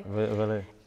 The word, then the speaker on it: Valee: Did I say it right, Valet? – Valet.